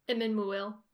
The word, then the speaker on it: Emyn Muil: Like when they're on or in Emyn Muil. How do you pronounce that? – Emyn Muil. – Okay. I think that's how I said it the, just a second ago.